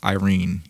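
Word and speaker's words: Eirene: I think it's pronounced Irene. Irene. It's E-I-R-E-N-E. – Mm-hmm. – Kind of like serene.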